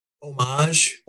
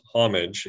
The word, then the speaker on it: homage: Homage is the French pronunciation. The American English pronunciation is homage. with a very, very light H, perhaps, homage, if you want to. – Yeah. – And apparently the British pronunciation of the word is a hard H, so it's homage.